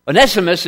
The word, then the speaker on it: Onesimus: Onesimus is the way that we normally pronounce it.